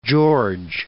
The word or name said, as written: Fort George